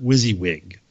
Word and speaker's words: WYSIWYG: And he said, I'm going to say that, pronounce that WYSIWYG.